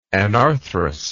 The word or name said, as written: anarthrous